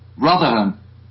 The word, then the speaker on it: Rotherham: Rotherham. – Spell that. – R-O-T-H-E-R-H-A-M.